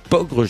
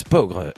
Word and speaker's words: Peaugres: Hervé, le parc animalier de Pogre, ça s'écrit Pogre, je dis pas de bêtises, P-E-A-U-G-R-E-S, Pogre.